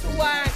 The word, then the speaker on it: Hawaii: It's Hawaiian coffee. – Hawaii. It's with the V. W is pronounced V.